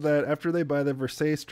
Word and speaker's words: Versace: No, because the next scene is them going to buy the Versace dress. – Yeah. – And it's revealed. – It's pronounced Versace. Sorry.